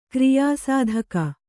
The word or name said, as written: ಕಾರ್ಯಸಾಧಕ